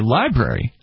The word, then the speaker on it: LBRY: Or a decentralized platform like, say, Library.